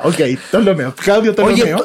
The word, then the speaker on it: Ptolomeo: El primero es Claudio Ptolomeo. Nunca he sabido bien cómo se pronuncian las P antes de las palabras. – No se pronuncia. Es como psicólogo. No se pronuncia.